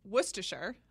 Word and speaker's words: Worcestershire: The engagement is announced between Kit, younger son of David and Deborah Harrington of Worcestershire, I think that's how you say it. – Yeah, it is.